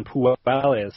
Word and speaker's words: Puelles: So let's go with the silent L man first. We'll break down Puelles first.